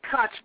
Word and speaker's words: Koch: If the Koch brothers, who don't even think black should be, and we should say their name right. You didn't say it right, sister. Koch brothers, they're Jews.